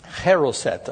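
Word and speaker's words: charoset: They would also eat heroset. I think that's how you say it. Pardon me, how do you say it? Heroset. That's it.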